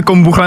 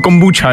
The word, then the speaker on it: kombucha: Vědci totiž zjistili, že bychom na Marzu mohli přežít díky, a teď doufám, že to přečtu správně, kombuše. Aha. Já nevím, jestli se to čte kombucha nebo kombuča, jo, já jsem našel, že čte se to oběma způsoby, to je jedno.